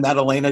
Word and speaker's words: Maddalena: I have with me the incredible Marianne Maddalena. Did I pronounce that last name right? – Yes, you did.